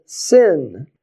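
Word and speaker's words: sin (שׂ): And if you put it on the left side of the letter, it's pronounced as a sin. So on the right side, an SH, and on the left side, an S.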